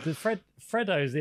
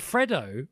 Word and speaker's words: Fredo: Fredo's in one of them. No, is it Freddo or Fredo? – Fredo. I think we just pronounce the chocolates Freddo, but Fredo.